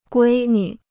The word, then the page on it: 闺女: guī nü